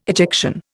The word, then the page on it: ejection: Indian